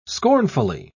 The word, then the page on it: scornfully: British English